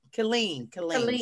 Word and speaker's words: Killeen: Killeen.